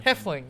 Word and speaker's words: Hoefling: So, you are Brian Hoefling. – Hoefling, yes. – Hoefling, I got it right. Yes. – Yes, the O is silent.